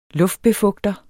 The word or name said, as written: luftbefugter